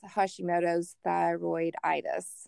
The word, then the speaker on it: Hashimoto's thyroiditis: Well, speaking of some darts maybe that you've been thrown, several years ago, you were diagnosed with Hashimoto's thyroiditis.